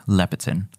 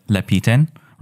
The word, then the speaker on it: Lepiten: Kirk Lepiten. – Is it Lepiten? – Yeah. – I mean, no, I've said my whole life Lepiten, right? – But okay.